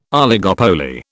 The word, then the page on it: oligopoly: British English